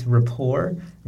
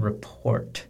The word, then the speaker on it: rapport: it has a T at the end, but it's a silent T. So it's difficult when we see it. So it's rapport, not report.